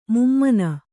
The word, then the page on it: ಮುಮ್ಮನ: mummana